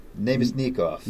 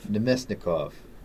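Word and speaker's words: Namestnikov: Nikita Kucherov, Steven Stamkos, Braden Point, Vladislav, and I always butcher this, Namesnikov. – Nemesnikov.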